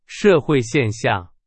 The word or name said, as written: 现象